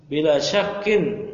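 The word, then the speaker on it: Bila syakkin (بلا شك): Ad'afu min nuri kitani fil hirzi wal adarti fastani bila syakka. – Bila syakka? Bila syakkin. Kenapa enggak mau di tanwin? Munsharif majrur. – Bila syakkin.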